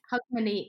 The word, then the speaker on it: Hogmanay: Hogmanay.